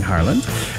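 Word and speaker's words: Haaland: Arling Harland, I've been learned how to say it. It sounds like Alan, Arling Harland.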